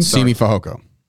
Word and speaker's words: Simi Fehoko: Simi Fehoko.